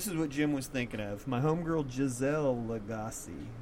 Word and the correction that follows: Lagacé: Legacy. I said it wrong. Legacy. Giselle Lagacé.